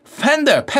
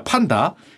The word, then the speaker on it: 판다: 그건 미국이고요 꼬레아에서는 판다입니다 팬더 아니에요 팬더 판다입니다 판다 그래서 이상하게 판다 그러면 좀 발음이 없어 보이는데 놀랍게도 표준어는 판다고 팬더는 판다의 비표준어입니다